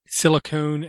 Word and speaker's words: silicone: It was make sure you pronounce silicone, and I can't say it. That's how bad I am. – Silicone and silicon. – Thank you. Make sure you say them differently.